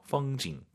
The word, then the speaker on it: 风景: 风景 올라간 순간 엄밀히 말씀드리면 틀린 발음이에요. 뒤에가 사성이잖아요. 반삼성이죠. 펑징 끝나야 됩니다.